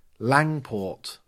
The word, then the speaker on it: Langport: Langport. How do you spell it? Is it Landport or Langport? – Lang, like Jamie Liang.